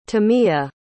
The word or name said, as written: tamia